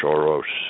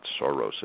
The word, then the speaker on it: Soros: and George Soros specifically. And by the way, that happens to be the correct Hungarian pronunciation. It's not Soros as we pronounce it in the U.S.